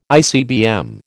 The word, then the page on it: ICBM: American English